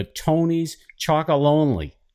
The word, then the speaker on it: Tony's Chocolonely: sales manager for Tony's Chocolonely. Did I say it right, Peter? How you doing? – That's correct. No, I'm doing great.